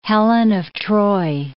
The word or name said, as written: Helen of Troy